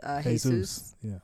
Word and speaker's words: Jesus: The J sound doesn't exist in ancient Hebrew. Kind of like when we hear people, Spanish people, they're closer to the pronunciation than we are. The Jesus. – Yeah. – But it would have been more like Jesus. Like Yeezy.